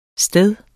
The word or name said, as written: STEDE